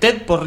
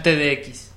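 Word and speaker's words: TEDx: ¿Te puedo decir cómo se dice en realidad? – Dale. – Es TED por Río de la Plata. No es TDX, es TED por Río de la Plata.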